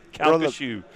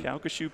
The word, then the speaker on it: Calcasieu: Calcasieu. – Calcasieu. – Calcasieu Parish, yes.